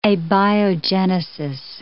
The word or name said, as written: abiogenesis